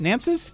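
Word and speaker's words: Nantz: Nance's? It's a Z, but it's pronounced like S. I'm going to go Nance.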